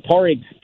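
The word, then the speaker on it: Pádraig: and then a beer we're calling Porrig Stout, named for obviously the Irish spelling and pronunciation of Patrick, which is a stout brewed with coffee, vanilla, and a little bit of chocolate as well.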